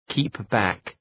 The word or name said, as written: keep back